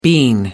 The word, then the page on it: bean: American English